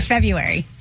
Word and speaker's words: February: Well, the question is, do you say the R? – No. – No one does. – Right? I've never heard anyone call it February.